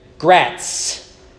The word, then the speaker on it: Gratz: And they would give the number and say, Gratz Road. And then mom would say, Gratz, Gratz, G-R-A-T-Z. There's a Z at the end, not an S.